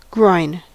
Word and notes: groin: American English; [ɡɹɔɪn]

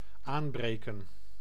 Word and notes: aanbreken: /ˈaːmˌbreːkə(n)/; NL

